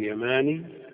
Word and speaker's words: يماني: لكن صنعاني هذه الألف مزيدة لا محالة، ليست مبدلة، ورازي الألف مزيدة، الألف والزاي مزيدتان وليستا ببدل، بخلاف النسبة إلى اليمن يماني، فالألف هذه بدل عن الياء الأولى من ياء النسب، ولذا الأكثر على أنها تنطق مخففة، ويخطئون من ينطقها مشددة.